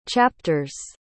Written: chapters

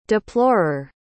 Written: deplorer

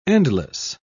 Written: endless

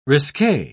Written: risque

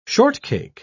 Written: shortcake

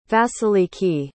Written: vasiliki

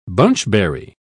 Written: bunchberry

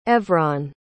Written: Evron

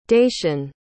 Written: dacian

